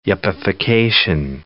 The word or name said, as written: yuppification